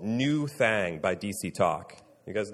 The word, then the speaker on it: Nuthang: This album is spelled N-U-T-H-A-N-G, New Thang. So that's why I'm saying it like that.